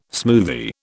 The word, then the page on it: smoothie: British English